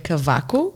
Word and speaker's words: Cavaco: My last name, you normally say it Cavaco.